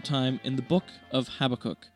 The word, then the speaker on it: Habakkuk: The Hebrew name is pronounced Habakkuk, in which the last syllable is emphasized.